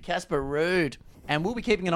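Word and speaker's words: Ruud: Casper Rudd is not Casper Rudd. It's Casper Rude, for starters.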